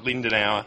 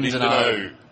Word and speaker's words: Lindenow: Lindenow. Not Lindenauer. No, Lindenow. Lindenow.